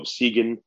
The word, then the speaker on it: Sigyn: He's the CEO of Seagen Therapeutics. That's spelled S-I-G-Y-N.